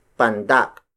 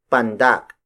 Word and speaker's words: pandak: The opposite of tangkad is pandak. Pandak. Pandak. Pandak is spelled as P-A-N-D-A-K.